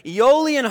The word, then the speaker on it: Aeolian: He says, comparable to some heavenly breeze set in motion by the Spirit of the Lord, it sweeps across the Aeolian, I looked it up, that's how you pronounce it, Aeolian harp strings of the apostle's soul,